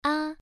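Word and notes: a: tone 1

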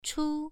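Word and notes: chu: tone 1

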